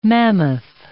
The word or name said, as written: mammoth